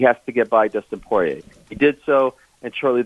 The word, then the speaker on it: Poirier: Yeah, you, the second one was right, Poirier. – Okay, so Poirier. – It's actually not like, if we were, it's a French name. He's from Louisiana, so it should be Poirier. – Yeah. – But, you know, they've anglicized it, as we say, so that's how he pronounces it.